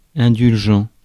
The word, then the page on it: charitable: [ˈtʃær.ə.tə.bəl]; American English